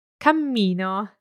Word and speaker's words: cammino: Cammino. – So I hope you can hear the difference there. Cammino, one M. Cammino, with two M's and a little bounce in the middle.